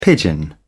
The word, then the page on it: pigeon: British English